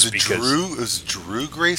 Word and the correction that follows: Dougray: Scott? – Dougray. It's spelled like Dougray, D-O-U-G-R-A-Y, but he's Scottish, so it's Dougray Scott.